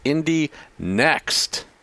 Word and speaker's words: Indy NXT: Indy Next. So it's all caps NXT minus the E, but they want us to pronounce it like the E is there, even though it's not. Even